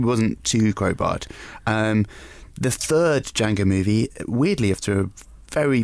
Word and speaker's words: Django: Anyway, Franco Nero obviously played Django. DJ. – No, he didn't. It was Jamie Foxx. – DJ N. – Or Tamura Morrison. – The D is silent.